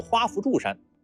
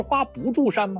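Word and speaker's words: 华不注山: 画左边长得像元宝的这座山叫鹊山，画右边长得像冰激凌甜筒的这座山叫花扶柱山。您可能已经有疑问了，那不是花不住山吗？怎么读成了花扶柱山了呢？因为这里的不原本是夫，有花萼的意思。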